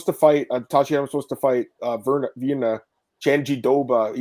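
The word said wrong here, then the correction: Jandiroba: How do you say it, Marcel? – Just like Janjiroba. Janjiroba.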